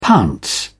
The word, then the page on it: pounce: British English